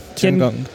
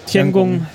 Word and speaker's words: Tiangong: Tiangong. Tiangong, du kannst Chinesisch, ich nicht. Tiangong 2 äh, gestartet. – Also normalerweise wird, werden die O's wie O's ausgesprochen, aber bei, bei Gung ist es halt Ung.